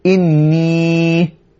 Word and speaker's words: إني: how would you pronounce that well one way of pronouncing it would be إني indeed I Indeed, I, verily I, inni min al-hindi.